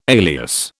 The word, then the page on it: alias: British English